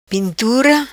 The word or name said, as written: Pintura